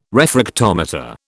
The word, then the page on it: refractometer: British English